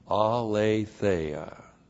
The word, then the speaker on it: aletheia: And then we have N plus the dative singular, which is the dative of sphere, in the sphere of aletheia. Aletheia, A-L, long E-T-H-E-I-A.